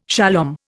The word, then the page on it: shalom: Indian